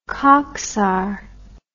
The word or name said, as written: Khaksar